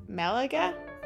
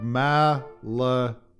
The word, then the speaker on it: Malaga: Meta, Malaga, right? What do you say? – Malaga? I found a pronouncer. Malaga. – I'm supposed to say it slow like that? All right. Popular destinations like Malaga.